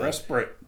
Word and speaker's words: respite: So respite. – They said that, believe it or not, the word does not rhyme with despite.